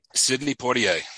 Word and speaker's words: Sidney Poitier: He's no longer with us, but his name is Sidney Poitier. I hope I pronounced that right. I looked on YouTube for the correct pronunciation. I think it's Sidney Poitier. – Sidney Poitier.